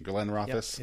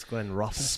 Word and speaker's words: Glenrothes: We did a pronunciation. Glenrothes. – Yep, it's Glenrothes.